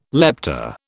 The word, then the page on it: lepta: British English